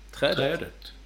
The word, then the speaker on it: Trädet: Uttalas med grav accent, så som i till exempel orden flötet och märket. – Trädet.